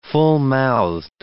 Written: fullmouthed

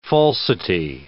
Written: falsity

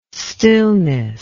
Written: stillness

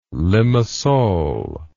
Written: limassol

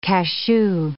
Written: cachou

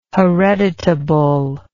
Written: hereditable

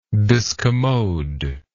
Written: discommodiousness

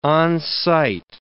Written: on-site

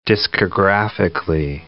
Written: discographically

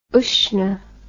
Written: Usnach

